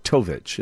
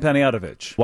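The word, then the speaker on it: Panayotovich: It's a T at the end. Tovich.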